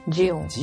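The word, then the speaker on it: Xeon: 28 コアの最新インテル、これなんて読むんだったっけ？ – ん？ – なんて読むんだったっけかな？ – どこ？ – 読み方。インテルの。 – インテル、ジオンだったっけ？ – ジオン？ジオン。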